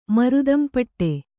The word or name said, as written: ಮರುದಂಪಟ್ಟೆ